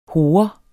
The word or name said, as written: hore